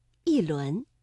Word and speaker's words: 一: 第二句“一轮明月”，这里的“一” 读四声，一轮。